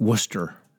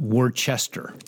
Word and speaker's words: Worcester: so it pronounces Worcester like Worcester, not Worcester.